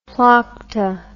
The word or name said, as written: plokta